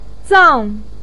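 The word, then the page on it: zang: tone 4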